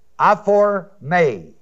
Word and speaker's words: aphorme: It is the Greek word aphorme, A-P-H-O-R-M-E, long E. Comes from apo, A-P-O, meaning away from. And the word hormao, H-O-R-M-A, long O.